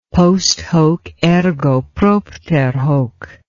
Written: post hoc ergo propter hoc

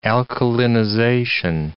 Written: alkalinization

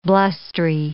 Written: blustery

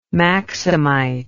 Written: maximite

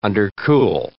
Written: undercool

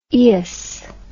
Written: eous

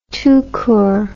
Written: tout court

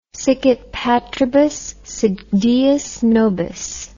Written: sicut patribus sit deus nobis

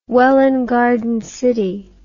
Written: Welwyn Garden City

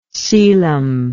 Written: celom

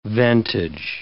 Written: ventage

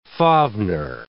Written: Fafnir

